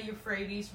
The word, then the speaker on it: Euphrates: Long ago, your ancestors, including Terah, the father of Abraham, and Noah, lived beyond the Euphrates. Is that how you say it now? – Euphrates River, and worship other gods."